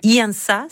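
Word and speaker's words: Iansã: Je pense que c'est un peu dans cet élan qui Iansa, c'est comme ça que ça se prononce.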